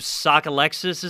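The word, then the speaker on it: Sockalexis: And they previously had a player that was on the Cleveland Spiders named Louis, I believe Sokolexis is the way you pronounce it, who's of Native American descent.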